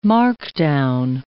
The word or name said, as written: markdown